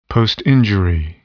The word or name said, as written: postinjury